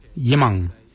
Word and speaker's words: yimoong: So for forehead, we say yimang. Yimang is spelled Y-I-M-O-O-N-G, yimang. Yimang is forehead. The next word is head. For head, we say kart. Kart. Kart is spelled G-A-A-D, kart.